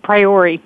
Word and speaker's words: Priore: Priore.